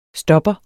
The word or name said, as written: STOPPEREN